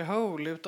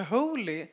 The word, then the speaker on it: Hole: Och ska försöka förklara att han inte heter Hole, och varpå de kallar honom för Holy, ja, hela boken igenom.